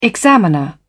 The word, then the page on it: examiner: British English